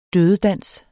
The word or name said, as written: dødedans